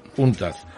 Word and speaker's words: UNCTAD: UNCTAD, creo que se pronuncia así. UNCTAD, sí. UNCTAD.